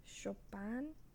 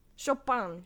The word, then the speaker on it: Chopin: Well, I guess if you want to go French, like his dad. Chopin. Chopin. Yeah. Chopin.